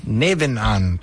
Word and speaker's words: Nebenan: And then I come back and I'm playing a show at Cafe Nebanon. I don't know how to pronounce it. – Nebenan? – Nebenan. Thank you.